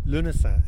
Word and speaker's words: Lunasa: Lunasa is how it's pronounced. – So a bit like lunacy?